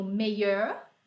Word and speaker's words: meilleur: Aí do outro lado a gente tem o meilleur, meilleur, meilleur e meilleur. Aí para ajudar a vida, eles têm a pronúncia idêntica.